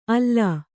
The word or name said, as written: ಅಲೆ